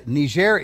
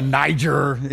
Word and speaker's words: Niger: By the way, uh, Niger is French, so you don't pronounce it Niger unless you're uh, American. Okay. Oh, did you go to Niger?